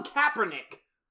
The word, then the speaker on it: Kaepernick: That's how you say his name, right? – Colin Kilpatrick. – Kilpatrick. They've subpoenaed Trump because they got an NFL owner under oath to say he did not sign Colin Kilpatrick. – Colin Kaepernick! – That's why I was asking you if I was saying it right.